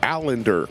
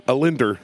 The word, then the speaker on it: Allender: Allender. – Allender. – Yeah, just do it without one L. – Allender. – Allender.